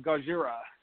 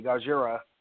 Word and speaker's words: Gojira: Well, speaking of Gojira, as the Japanese people say, Gojira. – That's racist. – Entertainment Weekly. That's what they say, Gojira. I don't know. – It's spelled like that, G-O-J-I-R-A, Gojira.